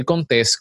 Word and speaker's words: Contés: Mi nombre es Miguel Contés con acento en la E.